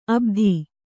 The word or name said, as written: ಅಬುದಿ